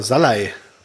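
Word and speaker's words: Szalai: Ja, und zwei Treffer von Schalai, ne? – Ja, genau. Salai. – Oder Salai, ja, sorry. Ja. Falsch ausgesprochen.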